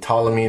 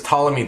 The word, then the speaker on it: Ptolemy: And Ptolemy, the P is silent, he was Ptolemy the Fifth.